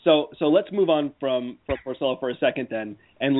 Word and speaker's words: Porcello: His name is pronounced Porcello.